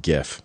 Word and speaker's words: GIF: Unless he pronounces the word graphics, fuck him. – See, that was always my logic, too. It's a graphic, not a graphic. But yeah, I don't know. – So GIF. It's pronounced GIF. And anybody who thinks differently is wrong and...